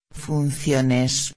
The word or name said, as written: funciones